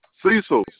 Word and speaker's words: Cecil: My name is Cecil Williams. – You say your name is whom? – Cecil Williams. – Cecil? – Cecil. C-E-C-I-L.